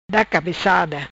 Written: Cabecada da

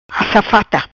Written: Assafata

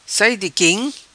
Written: Sai Di Quim

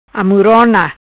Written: Amuirona

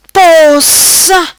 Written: Possa

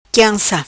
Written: quianca

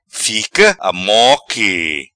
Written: Fica Amoque

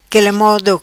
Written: Quele Modo

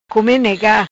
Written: Come Nega

